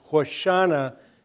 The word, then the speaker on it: Hoshana: Hosanna, in Hebrew, more correctly pronouncing Hoshana, means save us.